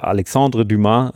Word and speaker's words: Alexandre Dumas: Die Geschichte ist halt hauptsächlich deswegen cool und erzählenswert, weil es die Grundlage ist für Alexandre Dumas und den Graf von Monte Cristo.